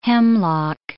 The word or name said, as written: hemlock